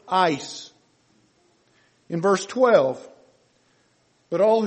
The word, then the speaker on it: eis: He uses the term ice, little Greek word ice. If you transliterate it, it's spelled E-I-S.